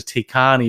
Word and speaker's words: Taycan: So the Porsche Taycan, let's call it the Taycan, even though it looks like it's spelled Taycan, looks gorgeous.